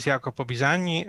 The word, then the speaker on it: Jacopo Bisagni: So my name is Jacopo Bisani, as Nike said. Pronouncing my name correctly, which is quite an amazing feat in itself.